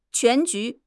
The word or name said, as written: 全局